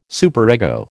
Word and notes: superego: American English

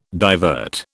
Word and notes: divert: British English

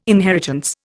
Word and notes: inheritance: Indian

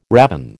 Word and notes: ravin: American English

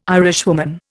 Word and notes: Irishwoman: Indian

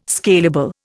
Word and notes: scalable: Indian

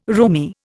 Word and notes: roomie: Indian

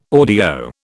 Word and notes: audio: British English

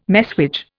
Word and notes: messuage: Indian

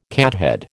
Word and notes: cathead: American English

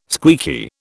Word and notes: squeaky: British English

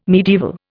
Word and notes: medieval: Indian

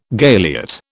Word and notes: galiot: British English

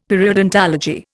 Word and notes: periodontology: Indian

